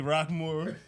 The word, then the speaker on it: Rockemore: First of all, the E in Rockemore is silent. – Oh, I'm sorry. – It's Rockemore. – I'm sorry. Anthony Rockemore, my good friend.